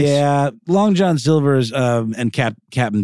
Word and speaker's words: Captain D's: It's pronounced Cap'n. It's like Crunch.